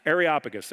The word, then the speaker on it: Areopagus: The Areopagus, I kept adding 2, 3, 4 syllables to it, and Areopagus, there it is.